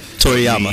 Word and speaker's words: Toriyama: Um, Akiriyami. I know uh, I said it wrong again, but it's, it's whatever. – Toriyama.